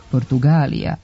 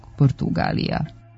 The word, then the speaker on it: Portugalija: Nedopustivo je u istoj pisanoj ili govornoj celini koristiti čas jedan, čas drugi oblik i duži izgovarati sa dugosilaznim akcentom na trećem slogu Portugalija umjesto pravilnog Portugalija.